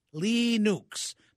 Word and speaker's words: Linux: If you're looking for Linux, and it's spelled L-I-N-U-X, and if we were going to spell it the way it was supposed to be pronounced, it would be spelled L-E-E-N-O-O-K-S, Linux. But nobody in America says that. We all call it Linux.